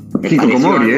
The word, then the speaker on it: hikikomori: pero este término de hikikomori, no sé si Hikikomori era.